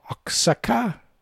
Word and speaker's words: Oaxaca: Regards from Oaxaca. Oaxaca, Mexico. Oaxaca? – Oh. – I don't know. Mexico. – Oaxaca, Mexico. – Okay. – I think the X is pronounced as an H.